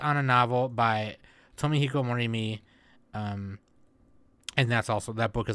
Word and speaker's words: Morimi: Morimi, there we go.